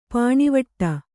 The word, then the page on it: ಪಾಣಿವಟ್ಟು: Kannada